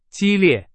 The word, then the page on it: 激烈: Chinese